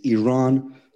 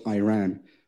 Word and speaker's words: Iran: Our country is named Iran, not Iran.